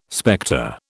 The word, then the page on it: spectre: British English